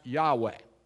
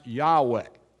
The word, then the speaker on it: Yahweh: And we think it was pronounced Yahweh.